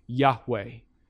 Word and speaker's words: Yahweh: Most theologians believe that it's pronounced Yahweh.